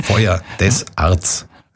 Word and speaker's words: Foyer des Arts: Foyer des Arts. Wie spricht man das aus? Na, haben wir schon gehört. Also das Foyer? Wie das Foyer? – Feuer. Feuer des Arts.